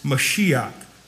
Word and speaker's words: Mashiach: And so whenever you look at the Hebrew, the word is actually Messiah, although the way you pronounce it, it's not just Messiah in the Greek, it's Mashiach. It's M-A-S-H-I-A-C-H.